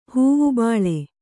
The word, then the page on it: ಹೂವುಬಾಳೆ: Kannada